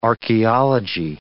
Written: archaeology

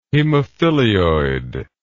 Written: hemophilioid

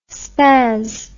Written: spazz

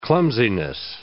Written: clumsiness

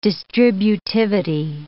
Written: distributivity

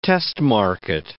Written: test-market

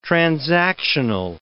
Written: transactional

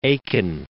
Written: Aiken

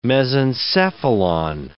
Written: mesencephalon

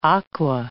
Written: aqua

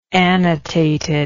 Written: annotated

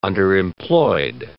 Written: underemployed

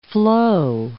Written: floe

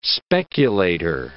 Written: speculator

